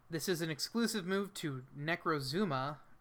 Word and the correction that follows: Necrozma: or Necrozma. I don't know how it's pronounced. – They didn't put the U there.